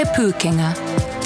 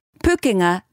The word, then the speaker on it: Pūkenga: This is Te Pūkenga, the New Zealand Institute of Skills and Technology. – Pūkenga means skills, pronounced pūkenga, pūkenga. Te Pūkenga.